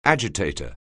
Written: agitator

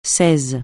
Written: seize

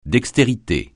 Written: dextérité

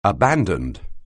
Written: abandoned

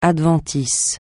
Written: adventice

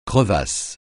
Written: crevasse

